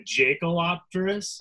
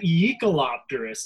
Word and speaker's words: Jaekelopterus: So why is it Yeek-a-lopterus? Does anybody know why it's spelled that way and it's ye? – Because the person who found it was named Yakel. – Yeah, so it's spelled that way, but it's pronounced Yakel.